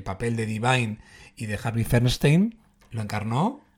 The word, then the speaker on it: Harvey Fierstein: Pues que Divine era una madre amantísima, maravillosa, que luego llegó a los escenarios encarnada por Harvey Fierstein, ¿no? ¿Lo he dicho bien el nombre? – Sí. – Bueno, correcto. Más o menos.